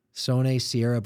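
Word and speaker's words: Sonae Sierra: Additionally, you've served on the NAREIT Executive Board of Governors, Governments Committee, board of directors of DDR, CubeSmart, Sone Sierra Brazil. Did I say that correctly? – You did.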